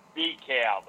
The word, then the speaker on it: DeKalb: Well, there's a P in pneumonia and a K in knife, so you don't pronounce those either. – No, I don't think we pronounce those either. – Checkmate. – No, it's DeKalb.